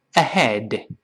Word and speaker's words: ahead: Go ahead，大家注意不要读 ahead，ahead，好多人把这个 h 读成呵呵，你注意读成呵，声带震动，而且你的这个这个舌根拱起来了。在英语中，这个 h，它在辅音中的发音都是呢，注意喉咙完全打开。